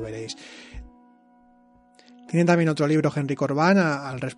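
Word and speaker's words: Henry Corbin: Si lo buscáis en internet, digo Henry Corbán porque creo que más o menos así se pronuncia en francés, pero eh, se escribe Corbin con B, Corbin.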